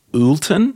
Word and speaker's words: Oulton: So for everyone, you're still at your thingy, just Cocoa Republic, or it's the Timothy Oulton, is that how you pronounce it? O-U-L-T-O-N? – Yep.